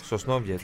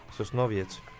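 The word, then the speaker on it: Sosnowiec: Σοσνόβιετς. – Τι? – Έχω φίλους από Πολωνία, ξέρω πολλές λέξεις. Σοσνόβιετς.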